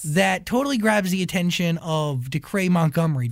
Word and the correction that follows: Dacre: Did I say his name right? – Dacre? Decray? Wow. No, it's Dacre.